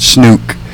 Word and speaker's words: snook: Can you say the word ook? – Ook. – Okay, now you put a SN in front of it. Snook. – Snook. – That is the correct pronunciation.